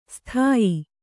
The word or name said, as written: ಸ್ಥಾಯಿ